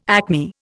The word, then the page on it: acme: Indian